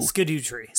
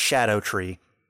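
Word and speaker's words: Scadutree: So this game uses a whole lot of old English spelling, so I don't know if they try to do it just to be cool or what, but it's supposed to be pronounced Shadow Tree.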